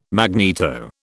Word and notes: magneto: British English

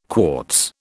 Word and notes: quartz: British English

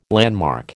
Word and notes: landmark: American English

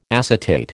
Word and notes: acetate: American English

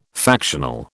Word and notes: factional: British English

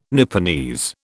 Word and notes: Nipponese: British English

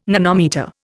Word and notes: nanometre: Indian

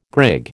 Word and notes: grig: American English

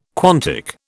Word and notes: quantic: British English

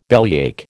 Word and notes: bellyache: American English

